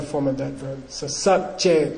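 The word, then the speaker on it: sa bcad: By the way, they're called sabje. Say sabje. – Sabje. – Sabje. Sabje. For those of you who care, it's S-A-B-C-A-D. Okay? So the prefix letter is pronounced.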